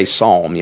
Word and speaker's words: psalm: I think it's funny, you know, Joe Biden made that statement this week, you know, the palmist, you know, doesn't know how to say psalm, you know, doesn't realize that the P is silent.